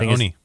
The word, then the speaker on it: Oney: You have the Undisputed Era taking on, I don't even know, Danny Burch and One Lorcan. – Yeah, Oney. – Oney Lorcan. That's how you say it.